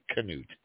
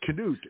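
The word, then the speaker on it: Knute: I always thought it was Newt my whole life, but here it's Canute. Who knew? – It depends on what part of the country you're from, I guess. I'm not sure. Yep, Canute is how they're pronouncing it here, and just like they used to call it, Dr. Jekyll and Mr. Hyde. I think a lot of people watered it down to Newt Rockne, but I must have been one of them.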